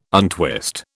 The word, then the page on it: untwist: British English